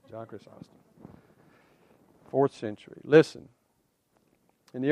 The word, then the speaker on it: Chrysostom: We go back and, and look at something that I, I pronounce it Chrysostom, okay? Chrysostom is the way it's pronounced, but I like Chrysostom better. It's easier for me to say.